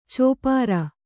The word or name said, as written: ಚಪ್ಪಿರಿ